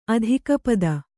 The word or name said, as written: ಅಧಿಕಪದ